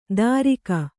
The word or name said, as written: ದಾರಿಗ